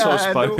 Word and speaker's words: Sosbo: I don't know how to pronounce your Twitter Sosbo.